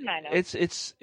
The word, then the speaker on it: Tenino: And I looked up where Tenino is, or Tenino, however it's pronounced. I am probably just butchered it. I'm sorry, but that's okay. It's Tenino.